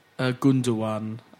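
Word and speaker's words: Gündoğan: Gundogan. – without the second G is not pronounced, as I understand it. Is that like Erdogan? – Yeah, exactly like Erdogan.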